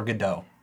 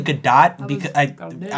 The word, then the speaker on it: Gadot: Gadot or Godot? It's actually Gadot. I remember hearing something in an interview where she pronounced it for somebody.